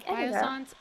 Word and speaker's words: Biossance: Yeah, Biossance.